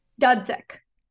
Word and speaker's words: Dudzik: Dudzik.